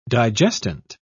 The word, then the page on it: digestant: British English